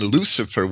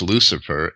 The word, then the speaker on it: Lucifer: The sea was originally hard in old Roman Latin, and you could read that in a lot of academic sources on the Latin language. And Lucifer wouldn't be pronounced Lucifer.